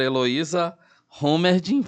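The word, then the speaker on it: Heloísa Hommerding: E hoje conosco a doutora Heloísa Homerdin, falei certo? Perfeito.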